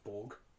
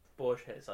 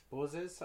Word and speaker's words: Borges: I don't think it's pronounced Borg. – Luke? – Borges. I pronounce it Borges. – Borges? Anyway, something like that.